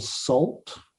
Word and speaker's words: Sault: The band is called Salt. – Salt. – And it's spelled with a U. That's an S-A-U-L-T. Salt.